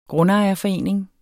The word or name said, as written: grundejerforening